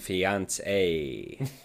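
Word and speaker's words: fiancée: Now, do you know how to pronounce them? – Fiance and fiance. – Yeah, perfect.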